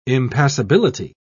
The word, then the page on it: impassability: British English